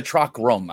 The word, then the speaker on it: chakram: It's a chakram. I'm sorry, I said it wrong. Chakram. Chakram.